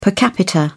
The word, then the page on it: per capita: British English